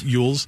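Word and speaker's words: Elwes: Cary Yules.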